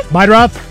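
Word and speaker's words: Meidroth: Chase Murdaugh? The White Sox guy? – Meidroff. – How do you say that? – Meidroff.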